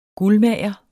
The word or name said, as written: guldmager